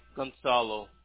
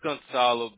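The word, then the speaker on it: Gonçalo: G-O-N-C-A-L-O. – C-A-L-O? – Yep. – How do you pronounce it? Gonzalo? – Yeah, it's Gonzalo, but it's supposed to have a comma, up uh-huh. front, but it does, under the C.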